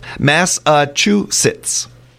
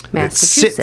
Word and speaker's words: Massachusetts: And it's pronounced Massachusetts. – Massachusetts. – It sits at the end there. That sits I never get.